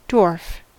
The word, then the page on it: dwarf: American English